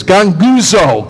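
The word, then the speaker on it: ganguzo (γογγύζω): It means to pronounce the word in Greek is ganguzo. Ganguzo. Sounds like a grumbling, doesn't it?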